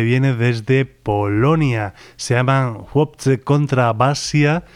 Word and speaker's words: Chłopcy: El nombre se describe de forma aparentemente muy distinta a como se pronuncia. Se escribe Chłopcy, algo así. O sea, una C, una H, una L con una rayita, una O, una P, una C y una Y.